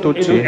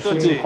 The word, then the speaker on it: Tutsi: Esa película sobre la lucha entre los Titsu, ¿no? ¿Cómo se dice? – Sí, los Hutu y los Tutsi. – Los Tutsi.